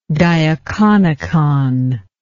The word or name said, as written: diaconicon